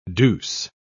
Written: douce

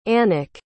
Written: annik

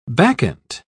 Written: bacchant

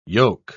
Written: yoke

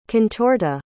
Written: contorta

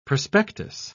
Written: prospectus

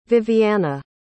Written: viviana

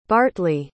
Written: bartleigh

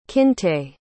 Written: kinte

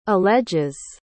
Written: alleges

